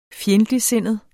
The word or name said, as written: FJENDTLIGTSINDET